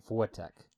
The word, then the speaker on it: Wojtek: It's like Wojtek. – Wojtek? – Like Wojtek. – Okay. – It's like a combination between the V sound and the W sound.